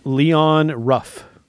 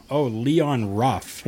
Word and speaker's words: Leon Ruff: Did you say his name right? Leo Rush? – Leon Ruff. – Oh, Leon Ruff.